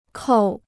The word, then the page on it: 扣: kòu